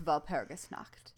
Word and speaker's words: Walpurgisnacht: Yeah, I put the emphasis on the wrong syllable. It's Walpurgisnacht.